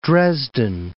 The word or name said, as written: Dresden